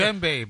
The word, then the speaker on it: djembe: It's a djembe, bro. Well, it's a djembe. Can you can you whatever? it, doesn't sound the way it's spelled, Jose.